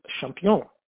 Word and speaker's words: Champion: Interesting that they decided they wanted to change the name of the town, and Adele suggested that they change the name to Champion, the name of the town in Belgium where she had originally planned to join the Ursulines. Of course, Champion, we pronounce in English, champion, and that's the name of the town to this day.